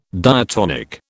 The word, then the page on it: diatonic: British English